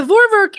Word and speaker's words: Vorwerk: It is spelled V-O-R-W-E-R-K.